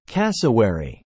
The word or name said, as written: Cassowary